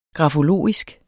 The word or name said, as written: grafologisk